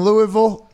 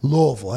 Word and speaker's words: Louisville: Let's go to Louisville. How should I pronounce that? – Louisville, that's what I thought.